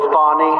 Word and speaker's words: Fani: You saw what happened in Atlanta with Fawny? F-A-N-I, Fawny. How do you pronounce F-A-N-I, Fawny? – What are you doing?